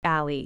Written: alley

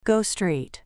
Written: go straight

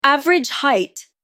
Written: average height

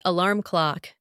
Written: alarm clock

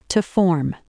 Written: to form

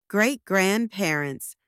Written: great-grandparents

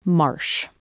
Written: marsh

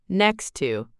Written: next to

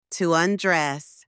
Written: to undress

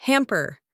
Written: hamper

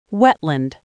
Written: wetland

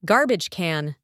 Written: garbage can

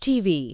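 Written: TV

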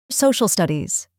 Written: social studies

